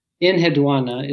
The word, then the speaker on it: Enheduanna: Is it Enheduanna? Is that how you pronounce it? – Enheduanna is typically how it's pronounced, but as with most things in ancient languages, it's sort of an approximation.